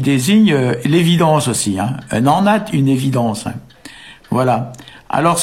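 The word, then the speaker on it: anat: Et donc en breton d'aujourd'hui, enat, qui s'écrit A-N-A-D ou A-N-A-T, D c'est plus proche de la prononciation, hein.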